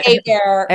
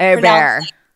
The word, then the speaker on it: Hebert: Because I'm gonna say it wrong. – Bobby Hebert. – Hebert. – I see Hebert, right? We're Cajun French.